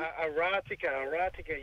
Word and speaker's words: Aratika: Now, just confirming the film, Aratika, Rise Up, is that the pronunciation? – Aratika, yeah, so it means rise up.